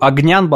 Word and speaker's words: Огнян: Огнян, болгарское имя, ударение на «я». А вот здесь написано через «е» просто, поэтому я говорю «огнен».